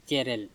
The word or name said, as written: ecErel